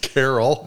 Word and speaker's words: Carell: I believe it's pronounced Carell. – No, it's actually Steve Carell.